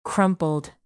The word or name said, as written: crumpled